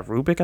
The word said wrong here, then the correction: Arabic: I think it's pronounced Arabic. Arabic.